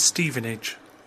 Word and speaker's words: Stevenage: The pronunciation is Stevenage.